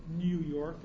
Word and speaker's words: New York: Anyone who speaks English properly knows that the EU sound in the English language is phonetically U. It's pronounced New York. Only Jews didn't pronounce it New York, they pronounced it New York.